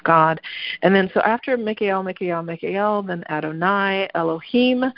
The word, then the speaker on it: Mikael: But especially when with the pronunciation of the L part of it, L is an ancient Hebrew name for God. So Mikael, Mikael, Mikael is the invocation of that light,